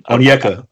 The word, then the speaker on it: Onyeka: Onyeka.